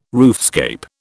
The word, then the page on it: roofscape: British English